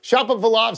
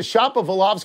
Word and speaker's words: Shapovalov: Wilbon, what do you think of Shapovalov's criticisms? – I think it's Shapovalov. Now you got me. I had it in my head, and now I got the wrong pronunciation.